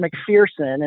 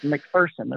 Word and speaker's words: McPherson: You know, because I tried to pronounce it McPherson, and they quickly corrected me. It's McPherson.